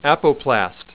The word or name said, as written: apoplast